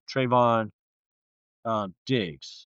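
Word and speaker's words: Trevon Diggs: However, they just picked up Trayvon Diggs.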